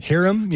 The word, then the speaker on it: Hiram: Bullock, how do you pronounce the first name? Is it Hiram? – Hiram, yeah. – Hiram Bullock.